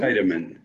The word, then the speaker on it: Scheidemann: Scheidemann. Is that how you say it? – Scheidemann, yeah. Scheidemann. Dutch name, Scheidemann.